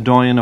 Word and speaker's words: doyen: About 5 years ago, at a function in Dublin, Padraic Colum was introduced as the doyen of Irish literature. Well equipped, I'm glad to hear that word pronounced properly.